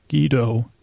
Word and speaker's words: Guido: It's Guido. G-E-E-D-O-H is how it was supposed to be pronounced, I guess, when I was listening.